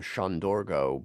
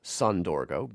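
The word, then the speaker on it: Söndörgő: That is a band out of Hungary, and I should probably pronounce their name Shandorgo, but inevitably here in the States it will be pronounced Sondorgo, begins with S-O-N.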